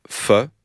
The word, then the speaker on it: pho: Alors là, on est au nord du Vietnam et on va goûter un pho. C'est comme ça qu'on prononce pho? – Pho. – Pho. – Pho. – P-H-O.